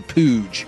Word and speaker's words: Pooge: I think we used to call Pooge like when you were little, like a little fat baby or whatever, like your Pooge. – Like Pudgy, but with a long U?